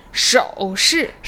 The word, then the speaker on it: 手势: 好的，很好，但是要注意它的声调是手势。